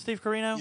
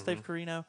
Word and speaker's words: Steve Corino: Was it Steve Corino? – Yes. – Am I saying that right? Yeah, mm-hmm. Steve Corino.